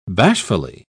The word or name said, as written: bashfully